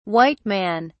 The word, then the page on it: white man: British English